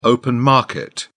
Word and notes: open market: British English

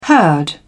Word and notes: herd: British English

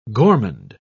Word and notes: gormand: British English

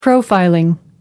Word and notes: profiling: American English

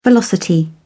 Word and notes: velocity: British English

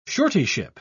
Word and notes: suretyship: British English

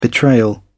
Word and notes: betrayal: American English

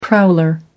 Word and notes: prowler: American English

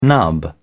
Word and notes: nub: British English

